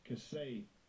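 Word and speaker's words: Kisei (כסא): Let's hear the pronunciation. – Strong's H3678. Kisei.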